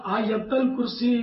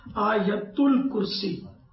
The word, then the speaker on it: آیت الکرسی: یہ آپ نے آیت الکرسی کا، آیت الکرسی نہیں کہیں گے، اس کا صحیح تلفظ ہے آیت الکرسی۔